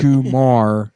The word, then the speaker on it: comare: C-O-M-A-R-E. – Yeah. – Oh, really? – Yeah. – Gumar. Gumar. – But the R can sound like a D.